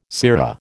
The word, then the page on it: sirrah: American English